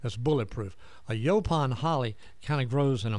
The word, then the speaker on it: yaupon: But Paul actually recommended using, I'm going to butcher it. Yaupon holly? – Yaupon. Absolutely. – Did I say it correctly? – Yaupon. Yaupon holly.